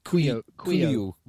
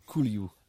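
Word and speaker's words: Couilloud: Yeah, Baptiste Caloud, however you say his name, when he came on, he looked... – Cuiu. – That's an incredible pronunciation. I would never have got there. – Cuiu.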